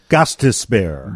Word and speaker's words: Gostisbehere: Let's go live to Dave Ennett for the pronunciation of Shane Gostisbehere.